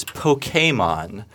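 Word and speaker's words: Pokémon: I think the proper pronunciation is Pokemon, hence the accent grave above the E.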